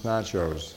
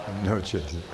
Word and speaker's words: noches: Buenos nachos. Noches. I always kids say buenos nachos, buenos noches.